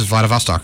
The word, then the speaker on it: Vladivostok: It is Vladivostok.